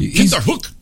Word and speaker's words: Kinderhook: Old Kinderhook. Let me see here, because... – You're saying it wrong. Kinderhook! – Kinderhook. – It's got to hit them syllables.